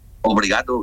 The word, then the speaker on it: obrigado: ¿Cómo, obligado, sí, ¿cómo se dice? Obligado es gracia, ¿no? – Obrigado, gracias. – Sí, obrigado. Hoy voy a tener que aprender portugués para hablar contigo.